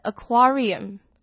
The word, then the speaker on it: aquarium: 那如果讲水族馆呢则是 aquarium， Aquarium，重音也是在 QUA 的地方，第一个 A 呢一样是发的音， Aquarium。